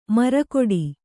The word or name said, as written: ಮರಗೋಡು